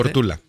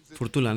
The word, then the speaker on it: Φουρτούλα: Δεν ξέρω πού τονίζετε να με συμπαθάτε. – Φουρτούλα. – Φουρτούλα, ναι. Γκάμπριελ Τόρες, Μαχαμαντού Ντιαρά.